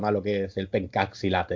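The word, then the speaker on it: pencak silat: Bueno, el, el, que, el, estilo de pencaxilat, porque se pronuncia así, son así de, de raros estos indonesios.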